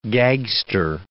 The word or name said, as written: gagster